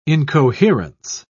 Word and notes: incoherence: British English

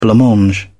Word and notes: blancmange: British English

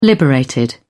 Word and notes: liberated: British English

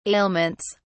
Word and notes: ailments: British English

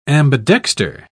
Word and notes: ambidexter: British English

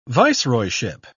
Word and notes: viceroyship: British English

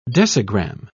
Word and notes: decigramme: British English

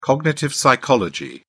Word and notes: cognitive psychology: British English

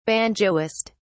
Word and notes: banjoist: British English

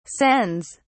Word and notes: sends: British English

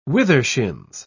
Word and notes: withershins: British English

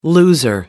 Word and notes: loser: American English

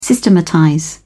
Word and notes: systematize: British English

